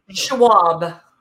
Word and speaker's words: Schwab: I always want to say your name, like your last name, wrong. I always feel like it's Schwab. – So like I'm like Sharab.